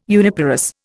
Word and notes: uniparous: Indian

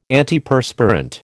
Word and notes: Antiperspirant: American English

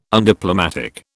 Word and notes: undiplomatic: British English

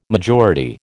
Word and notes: majority: American English

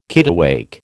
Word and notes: kittiwake: American English

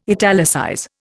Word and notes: italicize: Indian

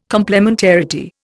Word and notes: complementarity: Indian

